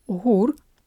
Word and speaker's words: GUR: The Navy of Ukraine and the main Directorate of Intelligence of the Ministry of Defense of Ukraine, or GUR, that's how you say it in Ukrainian, repatriated two Ukrainian paratroopers trapped behind enemy lines for 18 months.